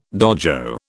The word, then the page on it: dojo: British English